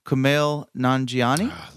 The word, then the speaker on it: Kumail Nanjiani: Jackson, Hugh Grant, Lisa Kudrow, Kumail Nanjiani.